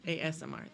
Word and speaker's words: ASMR: I don't know about the squishing part, but just making AMSRs. – There you go. Whatever that is. Can't spell that. – ASMR. – Yeah. – AMSR. – ASMR. – ASMR.